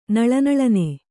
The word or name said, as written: ನಳನಳನೆ